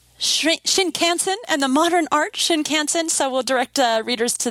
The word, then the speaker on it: Shinkansen: It's Shinkansen? Am I saying that wrong? – Yeah, no, that's right. It literally means new trunk line. – New trunk line. Shinkansen.